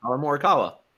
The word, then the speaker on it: Morikawa: Um, Pierre Gasly with I'm gonna butcher his last name, Collin Morikawa. Is that how you say yes, it? That was that. That was yeah, Collin Morikawa.